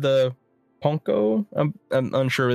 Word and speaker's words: Pwnco: This was known as the... Ponco? It's just P-W-N-C-O.